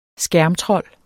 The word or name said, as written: skærmtrold